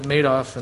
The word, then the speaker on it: Madoff: And if you're familiar with I guess they pronounce it Madoff, and that's because he made off with a bunch of people's money.